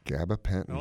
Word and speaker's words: gabapentin: I guess I'm pronouncing it right. Gabapentin. – Gabapentin.